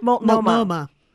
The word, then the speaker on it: Multnomah: I'm actually also doing an event on Monday at the, I want to say this right, Multnomah Athletic Club? – Multnomah.